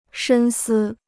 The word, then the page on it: 深思: shēn sī